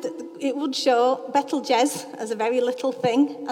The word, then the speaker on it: Betelgeuse: And then the red one up there, that's called Betelgeuse. Said it right. Looks like Betelgeuse.